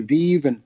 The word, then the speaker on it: Vive: it's Vive, and actually, this kind of started out. Brainchild is a lifestyle channel, and I think Danielle, wanted to call it something like Joie de Vive, and it turned into Vive, and so yeah, it's pronounced Vive.